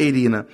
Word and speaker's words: Arena: Ok, amigos, essa linda canção, ela faz parte do disco Immortal, que é de uma banda de rock progressivo inglesa chamada Eirina. Na verdade, escreve Arena, mas a pronúncia certa é Eirina.